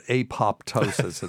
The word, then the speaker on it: apoptosis: But people will pronounce that apoptosis, and it's like, God, that just makes me cringe.